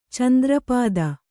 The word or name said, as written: ಚಂದ್ರಪಾದ